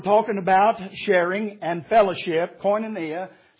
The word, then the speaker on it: koinonia: And it comes from a Greek word that is pronounced koinonia.